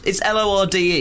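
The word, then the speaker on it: Lorde: Is it pronounced? It's L O R D E. The New Zealand guy is very passionate. – It's Lord, there. – Isn't it?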